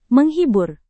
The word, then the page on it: menghibur: Indonesian